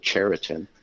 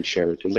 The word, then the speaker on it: Sheraton: that it's pronounced, it's now been told to me that we're supposed to pronounce it Cheriton. – Cheriton. – But anyways, Cheriton, yes. – Yes, Cheriton.